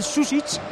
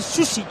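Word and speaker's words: Sučić: Te digo porque no se pronuncia como el mítico Safet Susic. Es Safet Susic, Susic. O sea, la dos ches, dos ches. – Exactamente, dos ches, Susic.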